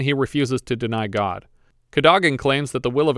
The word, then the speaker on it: Cadogan: I would have pronounced it Cadogan, but everyone here says Cadogan, so I'm going to go with them.